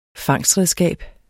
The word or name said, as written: fangstredskab